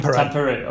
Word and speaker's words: Tampere: And also, I know that you connected with, I'm going to butcher the name of this city, but it's Tampere. Tampere, okay, in Finland. – Imagine how you would say it normally, then speed it up a bit. That appears to be how Finns talk. Tampere.